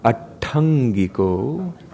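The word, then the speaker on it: aṭṭhaṅgiko: Ariyo, Atangiko, Atangiko. That's right, we need to pronounce both of them. Atangiko. In practice, it means a slight pause. You get your tongue in that position and you hold it there.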